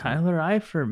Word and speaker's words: Eifert: I would say, with the exception of, guys, Tyler Eifert? How do you pronounce it? – Tyler Eifert, man.